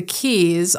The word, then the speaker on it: Quays: Go to the Keys, although they spell it Quays because we're Irish and we're weird, and get a pint or a few, enjoy some classic music, just have a good time, you know, go out and enjoy.